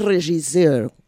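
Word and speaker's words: régisseur: Y es otra cosa que vi por ahí, pero no lo sé pronunciar, es regisseur. – ¿Cómo? Perfectamente pronunciado.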